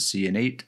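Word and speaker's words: Ciannait: And I watched a Bethesda live stream, and that's how one of the developers pronounced it, Cianate. So I guess that's how I'm going to pronounce it.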